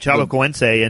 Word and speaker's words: Chapecoense: Look, Chapecoense, is that how you say it? – That's right, Chapecoense.